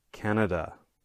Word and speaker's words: Canada: So the next one we have Canada. Okay, so please listen one more time. Canada. All right. So as you can hear, the stress is in the first syllable, can, that can.